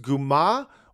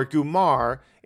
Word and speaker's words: comare: Comare means godmother. It's usually pronounced Guma or Gumar in American English, and it means like a mafia girlfriend.